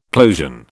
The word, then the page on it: plosion: British English